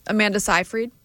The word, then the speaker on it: Seyfried: Amanda Seyfried.